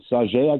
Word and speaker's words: Sauget: Watched the game last night in Sauget, I guess that's how you pronounce that. – Correct.